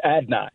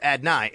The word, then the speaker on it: Adonai: It's Adonai. So just Adonai. Adonai Mitchell. – So it is three syllables, though. It's not two, Adonai. It's Adonai.